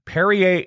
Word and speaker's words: Perrier: It's spelled like terrier with a P instead of a T. Perrier is a sparkling mineral water that comes from a naturally carbonated spring in Vergès, France.